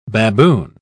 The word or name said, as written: baboon